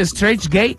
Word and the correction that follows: Straight Edge: Straight Edge.